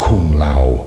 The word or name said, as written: kung lao